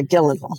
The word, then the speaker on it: Gyllenhaal: So you guys recently launched a campaign with Maggie Gyllenhaal. Is that how you pronounce her? – Yeah, Gyllenhaal.